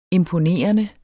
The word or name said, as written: imponerende